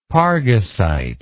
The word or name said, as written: pargasite